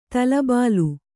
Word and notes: ತಲೆಬೆಲೆ: tale bele

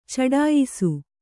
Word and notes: ಚಡಾಯಿಸು: Kannada

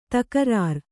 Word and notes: ತಕ್ರಾರ: Kannada

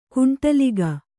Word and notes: ಕುಂಟಲಿಗ: Kannada

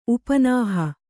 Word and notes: ಉಪನಾಹ: Kannada

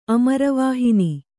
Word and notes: ಅಮರವಾಹಿನಿ: kannada

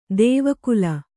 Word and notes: ದೇವಕುಲ: dēva kula

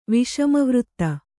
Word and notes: ವಿಷಮವೃತ್ತ: kannada

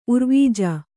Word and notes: ಉರ್ವಿಜ: urvija

